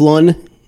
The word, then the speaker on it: Lunn: And also, is it Tim Lunn or Jeff Lunn? Rhymes with gun, they said.